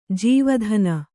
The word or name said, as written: ಜೀವಧನ